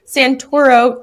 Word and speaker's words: Santoro: And she also has the Susan Santoro team.